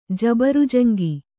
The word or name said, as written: ಜಬರಜಂಗು